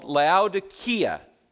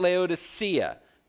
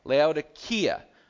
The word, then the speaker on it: Laodicea: The name of the city that the church we're reading about, the city is called Laodicea, not Laodicea. People pronounce it Laodicea, like that C in there is an S. It's a K, Laodicea.